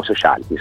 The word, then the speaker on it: Sociality: Intanto l'ho pronunciato bene? – Sì, almeno noi lo pronunciamo così, poi è libera interpretazione, ma anche noi lo pronunciamo Sociality.